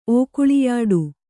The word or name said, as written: ಓಕುಳಿಯಾಡು